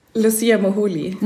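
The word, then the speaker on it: Lucia Moholy: Ähm, wie, wie spricht man den Namen richtig aus? Ich habe ihn nämlich noch nie gehört. Lucia Moholi. Moholi, mhm.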